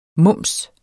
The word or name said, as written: mums